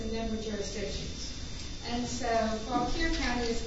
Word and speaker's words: Fauquier: That said, when they seek to expand their service, the DRE is not aggressively expanding, but they are talking now with Fauquier County.